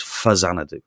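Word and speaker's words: Faxanadu: So we're all pronouncing it wrong. It should be pronounced Fuzanadu.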